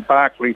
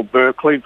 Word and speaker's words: Berkeley: He recorded Tapestry in 1969 in Berkeley, California, or Berkeley, depending on which way you want to pronounce it.